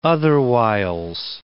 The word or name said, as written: otherwhiles